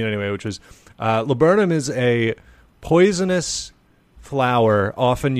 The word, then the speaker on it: laburnum: But also, like, the person who pronounced it, like, said it, like, they were like, laburnum. And it was like, okay, it's L-A-B-U-R-N-U-M, which is still very weird to me.